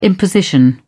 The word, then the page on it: imposition: British English